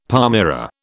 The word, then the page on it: palmyra: British English